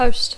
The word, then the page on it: host: English